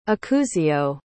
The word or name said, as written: acuzio